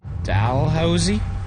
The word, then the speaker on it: Dalhousie: I mean, I know I don't pronounce it right, but I pronounce it Dalhousie. – What do you mean you know you don't pronounce it right? – Because people have told me it's Dalhousie.